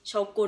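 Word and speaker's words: shogun: แต่พอเป็น h s h o g u n ออกเสียงมันสระอุแทนคือเป็นโชกุนไม่เป็นโชกัน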